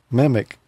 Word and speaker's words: MMIC: Sometimes it's pronounced MIMIC.